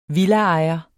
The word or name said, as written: villaejer